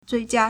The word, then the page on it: 最佳: zuìjiā